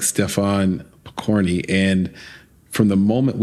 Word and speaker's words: Stefan: It is such a fun film about such an interesting gentleman, Stephen Pokorny. – Stefan. – I'm sorry. I actually have it spelled right in front of me and I said it wrong anyway.